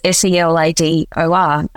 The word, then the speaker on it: Cellador: I set up my own distribution business called Cellador Wines, which phonetically, if you anywhere you pronounced it in Germany or Spain or France, it was Cellador.